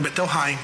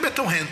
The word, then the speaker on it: Bethlehem: Mas não tem I aqui no meio, como Bethlehem. – É Bethlehem que se pronuncia. Pode ser Bethlehem também, não tem problema.